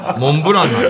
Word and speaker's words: モンブラン: モンブランなんだよ。 – モンブランです。 – もう日本中の人が間違えてるの。本当の発音はモンブラン。